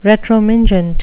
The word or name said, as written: retromingent